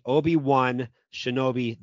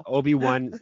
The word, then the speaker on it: Obi-Wan Shinobi: Obi-Wan Shinobi the Pillow. And it's Obi-Wan. The one is spelt like he wins. W-O-N.